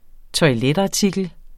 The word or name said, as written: toiletartikler